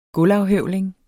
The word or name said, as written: gulvafhøvling